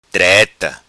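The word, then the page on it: Treta: Macanese Patuá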